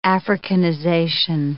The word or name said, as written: Africanization